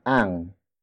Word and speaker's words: ang: And then we have ang. Ang. Ang. Ang is spelled as A-N-G.